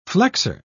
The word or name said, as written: flexor